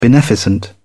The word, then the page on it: beneficent: British English